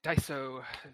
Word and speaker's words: Daiso: Am I pronouncing this correct? It's Daiso? – Yes, that is correct. Daiso, the mercantile texture and general goods store of Japan.